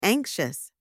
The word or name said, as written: anxious